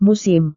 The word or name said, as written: musim